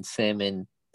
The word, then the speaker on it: salmon: Why is the L in salmon silent? – Why? Yeah. – If it was just S-A-M-O-N, it'd be pronounced the same. Salmon. – This is a very popular Google search. Originally answered on Quora, the L was never pronounced in English, and its presence in the spelling is the result of the same 17th century Latin obsession that gave us the B in debt and B in doubt. The L is the name of the fish is not pronounced.